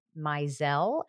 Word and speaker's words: Maisel: I never saw anything, but we were up in the Catskills shooting for the marvelous Mrs. Maisel, as I think that's pronounced. – Thank you. Thank you. Kudos on that pronunciation.